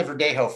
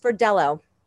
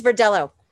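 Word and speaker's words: Verdelho: But tell me about the Verdejo first. – Well, it's actually pronounced Verdello. It's not a Spanish name.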